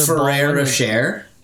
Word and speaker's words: Ferrero Rocher: Rocheshire, Rocheshire chocolate. Another Ferrero Rocher. – Ferrero Rocher. Yeah, that.